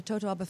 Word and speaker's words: Totoaba: Is that why the fishing of the Totoaba fish has just become rampant? – Well, it's a very interesting story, the tangled fate of Totoabas, which you pronounce perfectly, and vaquitas.